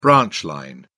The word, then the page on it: branch line: British English